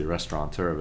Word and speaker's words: restaurateur: I say with an N, but there's no official N in that word. I say restaurateur, but it's actually spelt without an N.